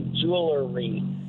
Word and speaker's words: jewelry: It's jewelry. Yeah, and she doesn't like people who say jewelry. – Oh, I see. Throwing it, because you do spell it J-E-W-E-L, right?